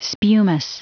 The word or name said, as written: spumous